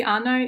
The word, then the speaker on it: Anna: It can be Anna. It can be Anya, which is like. Less well, Anya. – Formal. Yeah, this is quite like typical. Yeah, in Ukraine.